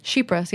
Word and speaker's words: Chypres: Chypres? How do you say that? – Chypres, yeah.